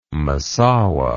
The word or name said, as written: Massaua